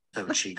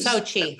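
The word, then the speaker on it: Xochitl: Wait, here's how you pronounce her name. They say Sochi Gomez. Sochi Gomez. – Sochi. – Now you know it's easy. Like and subscribe. – Okay, Sochi Gomez. I didn't realize that's how you pronounce it.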